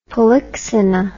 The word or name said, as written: polyxena